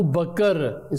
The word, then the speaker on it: Abu Bakr: So the correct pronunciation is not Abu Bakr, it's Abu Bakr. So Kaf and Ra are also Sakin, Bakr. So you join them all together, Bakr.